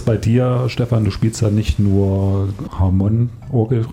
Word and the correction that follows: Hammond: habe ich das jetzt richtig ausgesprochen? – Gar nicht, das war alles falsch. – Ja, okay. Am besten nochmal. Aber du spielst ja nicht nur Hammond-Orgel, sondern auch Gitarre und so.